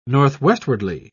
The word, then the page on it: northwestwardly: British English